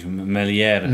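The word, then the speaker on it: Meslier: Melier, tak, bo już w tym tygodniu, jak oglądaliśmy mecz, to się zastanawiałem właśnie, czy się wymawia to jest, czy się nie wymawia? No nieważne. Jest taki nieme chyba.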